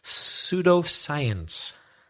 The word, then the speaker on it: pseudoscience: We've got a word which might be tricky to spell for some people, pseudoscience. The first letter isn't S, it's P. – It's a silent P. – P-S-E-U-D-O, science, pseudoscience.